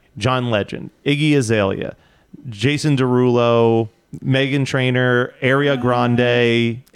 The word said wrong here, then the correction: Ariana Grande: But, you combine a Game of Thrones character with Ariana Grande, which I love it.